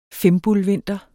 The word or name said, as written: fimbulvinter